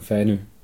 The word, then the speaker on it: Fanø: Du wohnst auf Fanø. Habe ich das richtig ausgesprochen? – Ja, vollkommen. – Wie würdest du es aussprechen? – Also die Deutschen sagen Fanø, die Dänen sagen Fanø. – Fanø, okay.